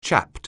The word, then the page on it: chapped: anglais